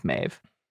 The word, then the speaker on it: Maeve: It's called Queen Maeve, and it gets its name from the female warrior deity from Ireland, Maeve. – Mm-hmm. – It's spelled M-A-B, but I believe it's pronounced Maeve.